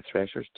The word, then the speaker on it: Thrasher: And if you want to find the show, you look it up, you can go to Google and Google HVAC with Rob Thrasher. Last name is spelled like the Atlanta Thrashers, T-H-R-A-S as in Sam, H-E Thrasher.